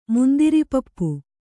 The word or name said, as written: ಮುಂದಿರಿಪಪ್ಪು